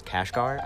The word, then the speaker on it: Kashgar: I don't know if I'm gonna say this right, it's Kashgar, I think I said it correctly.